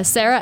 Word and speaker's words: Sara: Sarah, S-A-R-A. Last name, Delaverne, D-E-L-A. V as in Victor, E-R-G-N-E.